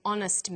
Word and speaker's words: honest: Where the consonant H is not pronounced, we use an. So we say, a happy man, but an honest man. The H is not pronounced, so honest sounds like it starts with a vowel.